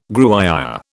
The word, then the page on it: Gruyère: British English